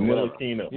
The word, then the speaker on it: Ntilikina: allegedly, a lot of NBA execs are kind of down on Kevin Knox, and they're not liking what they're seeing from Frank Ntilikina. I can't pronounce this guy's name. I hate that. It's Ntilikina something. – Ntilikina. – Whatever. – Ntilikina. Ntilikina. – What's it again? Ntilikina. – Ntilikina. Yeah.